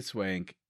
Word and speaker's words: Swank: Even when Hilary Swank is acting. – It's pronounced swank.